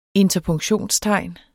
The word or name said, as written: interpunktionstegn